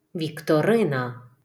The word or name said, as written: Вікторина